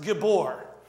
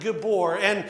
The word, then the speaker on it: gibor: And so I went and did a little bit of research, and the word for mighty in Hebrew is a word, and it's pronounced gibor. It sounds like gibor,